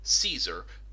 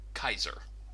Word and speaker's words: Caesar: The soft C, Caesar, is what my high school Latin teacher once referred to as church Latin, as opposed to the original Latin, which was Caesar, which, you know, is Kaiser.